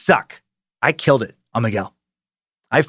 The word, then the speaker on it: Miguel: It's not Miguel, like how you've been pronouncing it. It's more like Miguel.